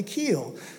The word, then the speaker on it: Kyle: If you knew me, even though my name is spelled as though you should pronounce it Kyle, that's not how you pronounce it. It's actually Keel.